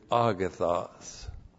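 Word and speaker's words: agathos (ἀγαθός): And then the objective negative, ouk with agathos. Look like that in the Greek and in the English. Ook. Ook is the objective negative. It slams the door. Absolutely nothing. And then with that, ook is agathos. A-G-A-T-H-O-S.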